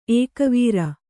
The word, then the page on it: ಏಕವಾರ: ēkavāra